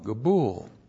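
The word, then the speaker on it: Gabul (גבול): Gabul. That's G, Shava, B, long U, L, Gabul.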